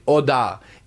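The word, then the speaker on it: Jodar: C'est J-O-D-A-R, c'est un espagnol, mais prononcé Hodar.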